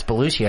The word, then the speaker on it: Belushi: He loved, but he would, what, he thought John Belushi was also Italian because he would pronounce his name. Who, Belushi? Dad, I think it's Belushi.